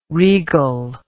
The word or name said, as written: regle